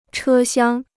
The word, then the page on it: 车厢: chē xiāng